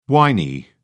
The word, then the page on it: whiny: British English